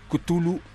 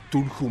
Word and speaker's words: Cthulhu: aparece en un libro compilatorio llamado Cthulhu Metal o Tulhu Metal, como se les dé la gana pronunciarlo.